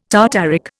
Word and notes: tartaric: Indian

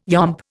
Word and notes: yomp: Indian

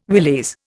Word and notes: willies: Indian

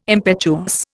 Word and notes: impetuous: Indian

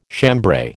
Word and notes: chambray: American English

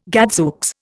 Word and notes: gadzooks: Indian

